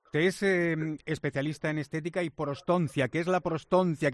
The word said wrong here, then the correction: prostodoncia: Bueno, a ver, la prostodoncia... – Prostodoncia, lo he dicho mal, ¿no? Prostodoncia. – Sí, es prostodoncia. Bueno, prótesis dental, prostodoncia, sí.